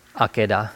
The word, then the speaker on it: Akedah: How do you pronounce that? Akedah? – Akedah, yeah.